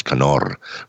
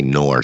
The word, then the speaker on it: Knorr: Today, and actually they roll the R's, Knorr, right? Sure. Today, in America, it's the silent K, Knorr.